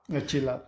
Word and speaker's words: Chilap: I think you pronounce it Sinlap. – Chilap, yeah.